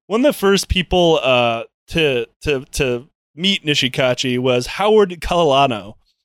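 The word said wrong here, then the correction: Kaleohano: Keely Ohano. That's the right way to say that.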